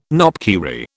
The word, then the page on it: knopkierie: British English